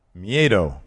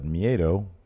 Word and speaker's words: miedo: How do you pronounce that? – Miedo. – Yeah. – Add an R in there and you got poo-poo. – Mm. – But Miedo.